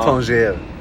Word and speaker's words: Tanger: Det är så det uttalas. Ja. Tanger.